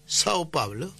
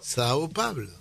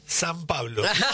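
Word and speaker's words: São Paulo: ¿Viste cómo pronuncia? Mira, aprende. ¿Podés repetirlo? – Sao Paulo. – A ver, ¿puedo probar? – Dale. – San Pablo. – No. Es que así se dice.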